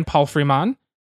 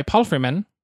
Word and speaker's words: Palfreyman: Editing is Johannes Grimm and Ryan Palfreyman. Oh, sorry, Palfreyman. There we go.